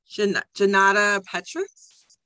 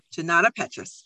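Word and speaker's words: Junauda Petrus: So this episode, we are going to be talking about The Stars and the Blackness Between Them, and that's by Janata Petrus. Okay, got it right. I was worried about getting her name right. So Janata Petrus.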